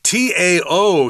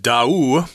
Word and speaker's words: Tao: Uh, if you look at that word without knowing how to pronounce it, you're probably going to say Tao. Mm -hmm. But uh, I've uh, been told that uh, the name of the people on the island. Uh, is Dao, like two syllables, Dao. So this means Dao, the people there and their fishing boats. – That's right. Uh, we use a T to um, uh, represent D, the sound at least of D in Chinese, like um, Dao, like, you know, you're following this wise path.